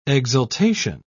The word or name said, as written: exultation